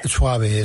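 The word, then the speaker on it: Schwabe: Creo que también estuvo ahí Carlos, este alemán, no sé si lo pronunciaré bien. – Carlos Suave. – Suave, eso es, ¿no?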